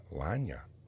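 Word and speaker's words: Iyanla: It's I-Y-A-N-L-A. Ilanya.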